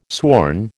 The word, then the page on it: sworn: American English